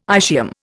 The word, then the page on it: ischium: Indian